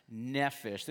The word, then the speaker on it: nephesh: It's pronounced nephesh. This is how it would look if you translate it, but it actually has an F sound.